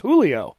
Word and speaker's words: Julio: J-U-L-I-O. – Yeah, and I think it's pretty fair for people to think it's his last name's Julio. His last name, the way they pronounce it, is Julio.